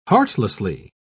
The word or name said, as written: heartlessly